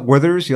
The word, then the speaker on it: Werther's: Do you like the butterscotch Werther's? You like that? Is that say it correctly? – Oh, the Werther's? – Did I say that correctly? – Werther's original? Yeah. Yes. Yeah, I like those.